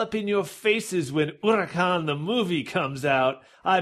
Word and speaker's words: Huracán: It's pronounced, that's flawless Spanish accent. It's Huracan.